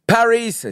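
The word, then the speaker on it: PVRIS: Paris,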